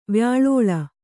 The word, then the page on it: ವ್ಯಾಲೋಲ: vyālōla; Kannada